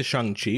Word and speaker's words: Shang-Chi: So, back to Shang-Chi, which I'm pronouncing properly.